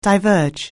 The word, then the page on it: diverge: anglais